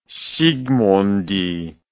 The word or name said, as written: zsigmondy